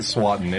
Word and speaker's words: Swanton: Swanton. It rhymes with rotten.